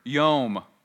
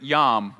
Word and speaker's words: yom: Well, the Hebrew word for day is the word yom, or sometimes we pronounce it yom, right?